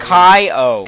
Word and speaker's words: Keo: No, it has it in parentheses how you're supposed to pronounce it. He was very particular. – Yeah, but I can't... I don't even know that. I can't Kai-O. – Kai-O. Okay, Kai-O. I'm sorry. – It looks like Kai-O.